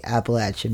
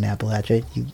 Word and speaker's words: Appalachia: because obviously I grew up in Appalachia. You did too. Mm-hmm. Appalachia mm-hmm. is what I always learned it as, but I guess that's a very regional pronunciation.